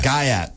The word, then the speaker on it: gyatt: Gaiat. – Gaiat means butt. – G-A-E-T? – G-Y-A-T-T. – G-Y-A-T-T. – When that's what you Gaiat. – Call... – Gaiat. – Gaiat. It's pronounced Gaiat.